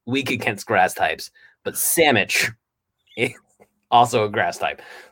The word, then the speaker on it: Salmich: It's called Salmon. – What's it called? – Salmon. – Salmon, yeah, just like. Sorry, called Samich. Okay. It's called Samich. But it's spelled S-A-L-M-I-C-H.